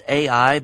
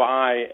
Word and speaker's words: Ai: I know that you folks are involved in some diggings over around the old city of Jericho and the city of, well, many of us refer to it as Ai, but I guess it's probably more appropriately pronounced I.